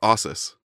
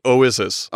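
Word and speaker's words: Oizys: No, I don't think I did. It's Oasis. Oasis. – I think it's Ossis. – No, it's O-I-Z-Y-S. – Oh. – Oasis. – O-Y-Z. Yeah, Oasis. – I looked up the pronunciation before and I forgot, but okay. I think it's Oasis.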